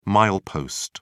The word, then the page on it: milepost: British English